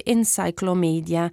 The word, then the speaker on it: Encyclomedia: e intorno a questo obiettivo nasce Encyclomedia, che ricorda enciclopedia nel nome, ma appunto si pronuncia così all'inglese.